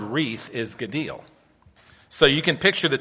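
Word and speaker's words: Gadil (גדיל): The Hebrew word is spelled G-A-D-I-L, and it's pronounced Gadil, Gadil.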